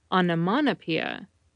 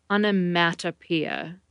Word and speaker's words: onomatopoeia: Onomatopoeia is commonly mispronounced with mana in the middle, onomatopoeia. Take care to put a mat in the word, onomatopoeia.